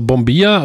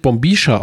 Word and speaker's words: bombilla: Ja, also, also Bombia, oder ich glaube, manchmal wird es Bombisha ausgesprochen, obwohl es mit Doppel-L geschrieben wird in der Mitte, aber manche sagen auch Bombia.